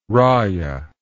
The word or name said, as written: raia